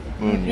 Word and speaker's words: Muna: And so it's pronounced Muna, not Muna.